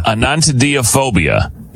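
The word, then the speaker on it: anatidaephobia: Anatidiphobia. – Anantidaphobia. – Felt that on my neck. – Yeah, hello. – Anantidaphobia. Phobia. – Anantidaphobia is what it is, and that's how you say it.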